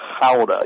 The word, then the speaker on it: Gouda: You would, if you looked at it, that's where the cheese Gouda is made, and it's not Gouda in the Netherlands. In the Dutch, they call it Gouda. You got to kind of grunt a little bit as you say it.